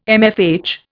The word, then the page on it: MFH: Indian